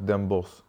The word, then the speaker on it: Den Bosch: Is it Bosch or Bosch? Everyone pronounces it different. – It's normal in Dutch, you talk Den Bosch. So it's Bosch.